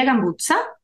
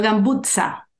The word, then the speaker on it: Gambuzza: No podían pronunciar mi apellido. Imagínate a mis estudiantes americanos diciendo Gambutza. Decían cualquier cosa, cualquier cosa menos eso.